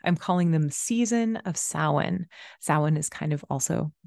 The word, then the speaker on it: Samhain: Um, so that's it, it, looks like it's spelled Samhain, but it's pronounced Samhain.